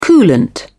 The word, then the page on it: coolant: British English